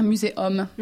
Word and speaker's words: muséum: Ensuite, nous avons les mots latins francisés et bien ancrés dans notre langue, comme muséum, dont oui. – le U, en fait, se prononce un peu comme un O ouvert. – Tout à fait, A. – Un muséum, voilà. À ne pas prononcer oum, comme dans un muséum, parce que c'est ridicule.